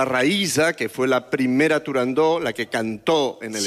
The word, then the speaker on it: Turandot: Turandot. – Turandó. – Turandot. – No. – Turandó. – No. No se debe pronunciar Turandot. – Ah, no, no. Turandó. – La ópera se titula Turandó.